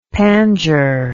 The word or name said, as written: pandure